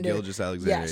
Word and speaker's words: Gilgeous-Alexander: Gilgeous-Alexander.